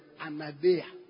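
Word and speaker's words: anaideia (ἀναίδεια): That word importunity is the Greek word anaideia.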